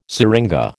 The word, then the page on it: seringa: American English